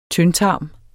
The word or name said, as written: tyndtarm